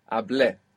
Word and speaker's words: hablé: Well, the accent is on the E. – Perfect. Hablé.